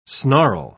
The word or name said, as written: snarl